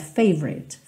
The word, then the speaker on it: favorite: Esse O do favorite não é muito pronunciado, tá? Então, você meio que passa direto, favorite, favorite song.